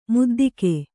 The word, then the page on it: ಮುದ್ದಿಗೆ: muddige